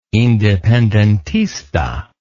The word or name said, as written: independentista